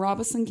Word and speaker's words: Robeson: I am originally from Robeson County. And if you're from there, you know that that's how we pronounce it.